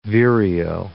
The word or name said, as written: vireo